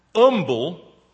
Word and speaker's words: humble: The word humble originally from the Latin H-U-M-I-L-E-M is where we get the word humility. But it is interesting that the word humble was frequently spelt and pronounced umble from medieval times right down to about the 19th century.